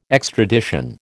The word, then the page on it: extradition: American English